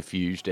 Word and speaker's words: Fuest: Yeah, I'm going to say Fust, F-U-E-S-T.